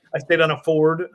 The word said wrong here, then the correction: fjord: I don't know how to say that right, but Fjord. Uh, fjord. Fjord.